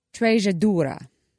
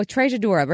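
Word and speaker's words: Treixadura: This one's called Paso de Mar, and it is called, Lord help me, Trejadura. And it's spelled Trixadura. – Oh, you threw me off with the X. – I know. So, Trejadura, we're going to go with that.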